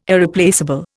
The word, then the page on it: Irreplaceable: Indian